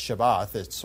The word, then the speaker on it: Shabbat: It's a Hebrew word that's pronounced Shabbat, or Sabbath.